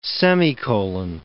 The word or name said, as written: semicolon